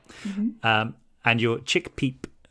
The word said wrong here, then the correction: Chickpeeps: Did I pronounce that right? – Chickpeeps. Yeah.